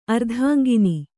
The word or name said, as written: ಅರ್ಧಾಂಗಿನಿ